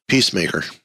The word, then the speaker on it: Peacemaker: Why did I say Pacemaker? I even saw the E, but didn't want to pronounce it. – That was good. – Peacemaker and the Penguin.